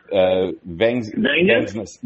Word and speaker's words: Vangsness: Kristen Vangsness.